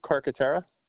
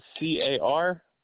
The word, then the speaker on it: Carcaterra: Name is Matthew Carcaterra. – Spell that for me real quick. – Yep. Last name is Carcaterra.